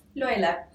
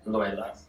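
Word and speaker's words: löyly: So the steam in the sauna in the Finnish tradition is referred to as Loila. – Loila. – Loila. – L-O-L-Y.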